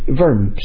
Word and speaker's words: Worms: Worms, or a W in German, is pronounced as a V, Worms.